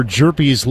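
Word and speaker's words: Hjerpe: Jerpy now to Wilkin, and the pitch is taken up and in for ball one. Cooper Jerpy's last name, by all accounts, would have to be of Nordic or Swedish origin.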